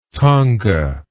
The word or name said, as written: thonga